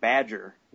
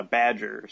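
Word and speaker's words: B'dg: It's B-apostrophe-D-G. – I think it's supposed to be like Badger.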